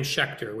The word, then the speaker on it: Schecter: And my last name, Schecter, which is spelled S C H E C T-E-R.